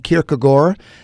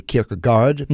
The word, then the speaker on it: Kierkegaard: Yeah, I would say Søren Kierkegaard. That's how the Danes pronounce it. We say Kierkegaard because mm-hmm. we pronounce the D.